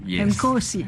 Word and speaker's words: Nkosi: Nkosi. Il n'y a pas de clic. No clic.